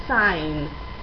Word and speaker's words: sein: La EI, el diptongo EI, se pronuncia como AI. Por ejemplo, sein. Mein Name, mi nombre. Sein Name, su nombre. Recordad siempre, EI se pronuncia como AI, sin excepción.